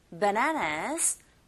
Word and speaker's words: bananas: 그리고 바나나가 아니라 bananas. Bananas 강세가 뒷부분에 있고요.